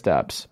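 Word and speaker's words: Doubs: Is it it is Dubs, right? – Like I'm pretty sure it's Dubs, but it's spelled like Dubs.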